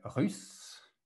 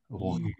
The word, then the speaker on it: russe: Another pronunciation exercise, the U, it sounds like ou. It's like U. U. You want to make your mouth very small and say like U. Russe.